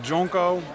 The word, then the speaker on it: DJ Onko: En DJ Onko spreek je uit als Jonko.